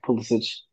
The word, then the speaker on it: Pulisic: Christian pronounce his name, but no, you pronounced it right the first time, Pulisic.